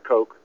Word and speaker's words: Koch: Yeah, I've read about Dr. I always called it Coke, but yeah, it's spelled K-O-C-H, and yeah, glyoxylide was his treatment.